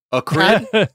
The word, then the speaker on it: acrid: Is the word pronounced acrid? Acrid? – It's acrid.